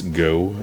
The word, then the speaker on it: Gough: Denise Gough, I think that's pronounced. G-O-U-G-H.